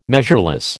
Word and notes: measureless: American English

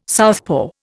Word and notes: southpaw: Indian

